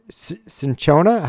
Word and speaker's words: cinchona: Maybe this is something different, but it's spelled C-I-N-C-H-O-N-A. That was different than the caquina, right? – Yes, I believe so. – Okay. So not caquina, but cinchona.